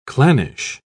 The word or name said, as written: clannish